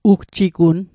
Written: ugjigunn

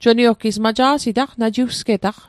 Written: najiwsget

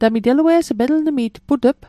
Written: put'p

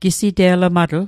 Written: gisite'lmatl

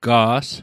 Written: ga's

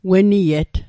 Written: weniet

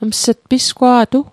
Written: ms't

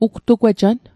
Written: ugtugwejan

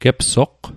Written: gepsoqq

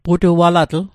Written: putualatl

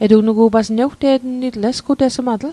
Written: esgutesmatl

